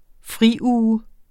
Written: friuge

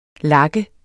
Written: lak

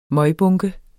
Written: møgbunke

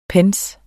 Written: PENNY

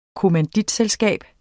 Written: kommanditselskab

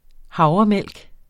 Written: havremælk

